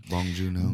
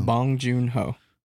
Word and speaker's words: Bong Joon-ho: I was saying Joon-ho, but it's Joon-ho. So. – Joon-ho. Bong Joon-ho. – Bong Joon-ho.